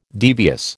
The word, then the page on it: devious: American English